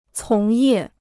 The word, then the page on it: 从业: cóng yè